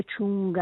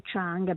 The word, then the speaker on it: Chung: perché perché comunque Chung, anzi Chang, perché adesso dobbiamo imparare a pronunciarla alla coreana.